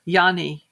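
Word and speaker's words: Jenny: Yanni, which is spelled J-E-N-N-Y. J-E-N-N-Y. And the last name is D-O-L-F-E-N.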